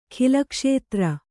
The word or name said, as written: ಖಿಲಕ್ಷೇತ್ರ